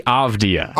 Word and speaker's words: Avdija: Denny Avdija. – Avdija. There's a lot of consonants. – Avdija. – Avdija. – It's actually as many vowels as consonants. – Denny Avdija.